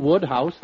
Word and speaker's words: Wodehouse: Woodhouse, that's how it's pronounced.